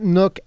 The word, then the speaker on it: Nuuk: Not Nuuk as in a little reading nook to sip your coffee in. No, it's not like that. Although they probably have one of those there. – It's spelled N-U-U-K.